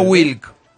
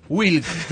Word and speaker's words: Wilk: Descontó Lanús ahora con gol de Alan Wilk, un futbolista paraguayo que cuyo apellido es WLK. – Mira. – Sin vocales. Se pronuncia Wilk. – Ah, mira. – WLK. – Gluck. – Wilk.